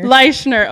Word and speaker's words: Leischner: Okay, is it Leischner? – It's Leischner. – Leischner.